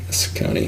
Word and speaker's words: Saucony: Did I say it wrong? Is it Saucony? Saucony.